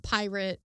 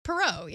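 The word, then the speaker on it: Pierrot: It's Pirot, like, it's not pirate, but... – Yeah, the pirate clown. – The pirate clown. This has gone off the fucking rails. – Pirot. – It was Pirot, yeah.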